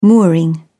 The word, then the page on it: mooring: British English